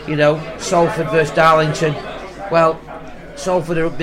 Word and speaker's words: Salford: Salford.